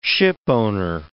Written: shipowner

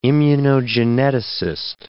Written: immunogeneticist

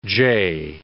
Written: jay

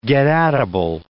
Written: getatable

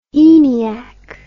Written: eniac

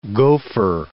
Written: gofer

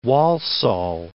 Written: Walsall

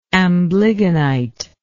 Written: amblygonite

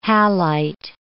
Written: halite

